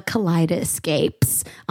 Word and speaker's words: Kaleidoscapes: It's like a collide, like an escape in a kaleidoscope. So kaleidoscape, if you think about it like that. – Oh, emphasis on the escape.